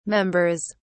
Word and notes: members: British English

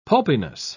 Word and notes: pulpiness: British English

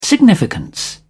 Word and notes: significance: British English

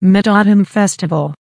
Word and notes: mid autumn festival: American English

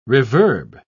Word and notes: reverb: British English

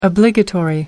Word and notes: obligatory: American English

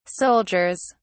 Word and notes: soldiers: British English

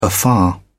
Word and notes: afar: English; British English

